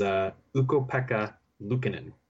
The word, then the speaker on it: Ukko-Pekka Luukkonen: is Ukko-Pekka Luukkonen.